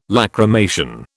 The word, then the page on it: lachrymation: British English